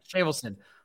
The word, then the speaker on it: Shavelson: If you hung around for an hour and 31 and don't know, how do you pronounce it? Shavelson? – No, you nailed it. Shavelson. Yeah, good job. – Shavelson.